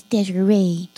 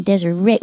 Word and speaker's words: Desiree: Well, it's an emphasis on the end. Desiree. Well, thank you for the water. Desiree. Yeah. – Desiree. – Yes, it's like a ray of sunshine, like you desire the sun. Desiree. – Like R-A-Y. – Oh, yeah, Desiree. – R-A-D-E-S-I-R-A-Y.